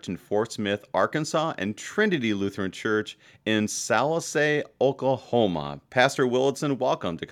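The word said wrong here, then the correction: Sallisaw: Now, your congregation in Oklahoma, did I say that right? – It's a Trinity in Salisaw. – Salisaw. Okay, very good.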